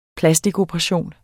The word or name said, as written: plastikoperation